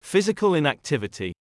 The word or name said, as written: physical inactivity